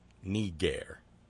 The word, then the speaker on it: niger: who learn that the Latin word for black is N-I-G-E-R. Niger. We know that's how it's pronounced because we were given pronunciation rules by ancient Roman and Latin writing authors.